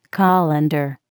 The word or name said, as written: colander